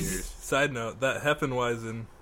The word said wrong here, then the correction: Hefeweizen: It's pronounced Hefeweizen. – Hefeweizen.